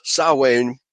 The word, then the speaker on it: Samhain: Now, that's very interesting because Samhain, as it's correctly spelled Samhain, pronounced Samhain, and other pagan traditions, many of them were started in Germany.